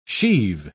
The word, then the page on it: sheave: British English